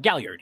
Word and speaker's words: Galliard: So the ones born beneath the gibbous moon are, I probably butcher this as well, Galliard? – Galliard, yeah.